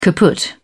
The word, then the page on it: kaput: British English